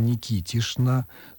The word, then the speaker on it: Никитична: Пишется Никитична, читается Никитишна, но есть и вариант Никитович и даже Никитовна.